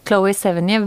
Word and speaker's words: Chloé Sevigny: Men um, Chloe eh, Chloé Seven... Jeg vet ikke hvordan man uttaler det. Sevigny. – Chloé Sevigny.